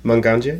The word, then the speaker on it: manganje: Okay, so this is a manganje. – Manganje? – Yes. Manganje. – You can say if I said it wrong. Just say no. – No, it's manganje.